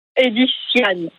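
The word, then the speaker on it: Ediciane: Edisiane. – Edisiane. Comment vous l'écrivez? Oui. E-D-I-S-I-A-N-E? Edisiane, c'est ça? – C-I-A-N-E.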